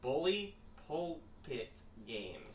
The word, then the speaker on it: Bully Pulpit Games: But in the meantime, as a little buffer game, just a one off, we are playing Fiasco by Bully Pip Games. – By what? – Bully Pulpit Games.